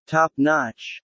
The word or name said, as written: Top notch